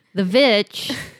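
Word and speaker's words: VVitch: Because in The Witch, I'm sorry, I keep saying it wrong. The Vitch, they're like old-timey American settlers.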